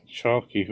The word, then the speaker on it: Chalke: I thought you were going to say, like, Sarah Chalke, who played Elliot, or Judy Reyes, who played Carla. – Sarah Chalk. – That's how it's pronounced.